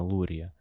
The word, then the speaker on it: Лурье: А, еще Самуил Лурье. А, по-моему, я правильно поставил ударение, там именно Лурье.